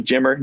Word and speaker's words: Jimmer: And it sounds like Jimmer, Jimo. And that's how people kind of pronounce my name over here. They can't really pronounce the ER.